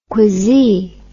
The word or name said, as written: quizzee